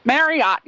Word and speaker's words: Marriott: at the Marriott, is that how you say it? Marriott. Yeah, that sounds good.